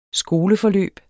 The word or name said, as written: skoleforløb